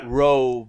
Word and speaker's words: Roe: How do you say it? Roe? Like R-O-D? Or Roe, R-O-A-D? – Roe. – Roe. Like Roe. – R-O-E. – R-O-E. R-O-E. R-O-E. – R-O-E. R-O-E. – R-O-E.